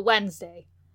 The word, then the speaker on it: Wednesday: But Wednesday. Yeah. You're going to pronounce it like the N's before the D, but you're going to spell it with the N after.